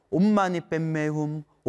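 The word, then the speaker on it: 옴마니반메훔: 옴만이 밤매움, 옴만이 밤매움, 옴만이 밤매움. 티베트 발음으로는 옴만이 뱀매움, 옴만이 뱀매움, 옴만이 뱀매움.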